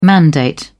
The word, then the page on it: mandate: British English